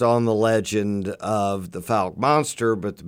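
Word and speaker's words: Fouke: Falk. – Yes. – That's how it's pronounced. Falk, Arkansas.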